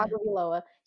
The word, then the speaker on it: Tagovailoa: Jalen Waddle and Tua Tagovailoa. I think I said that right. – Yeah. – Tagovailoa.